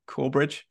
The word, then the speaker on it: Corbridge: Have I pronounced that correctly? Corbridge? – Yes, it's the same place that one Pete Graves was born.